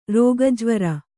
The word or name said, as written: ರೋಗಜ್ವರ